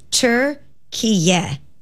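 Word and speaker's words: Türkiye: the country's recognized name is Turkiye.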